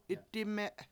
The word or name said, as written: 7itdIme7